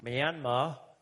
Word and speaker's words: Myanmar: In Myanmar, the R is silent, that's the former Kingdom of Burma, now it's got its new name.